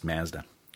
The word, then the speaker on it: Mazda: And by the way, Mazda in New Zealand is usually pronounced Mazda.